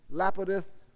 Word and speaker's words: Lapidoth: Lipidoth, something like that. Lapidoth.